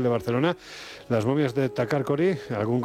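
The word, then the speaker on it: Takarkori: Las momias de Takarkogri. Como lo he dicho mal, ¿no? – Bueno, si te contentas con eso, tú misma. – Takarkogri. – Ahí, muy bien.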